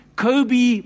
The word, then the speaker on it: Kobe: Am I right in calling him Kobe Bryant? I'm looking at Jabu. How do you say that, Jabu? Is it Kobe? Kobe Bryant.